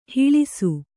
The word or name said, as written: ಹೊಳೆಸು